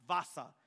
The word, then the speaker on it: Vasa: In 1626 to 1627, the king of Sweden, Gustavus Adolphus, was expanding his military, and he built a ship called the Vasa, W-A-S-A, but pronounced Vasa.